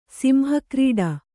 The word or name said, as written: ಸಿಂಹಕ್ರೀಡ